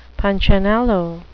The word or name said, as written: punchinello